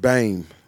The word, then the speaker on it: Bem: Well, it's B-E-M, and it's pronounced Bame.